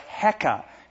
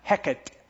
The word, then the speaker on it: Heka: And that was the frog goddess, Heka. If you wanted to spell it, I suppose it would be H-E-K-A, sometimes pronounced Heket. Heket or Heka.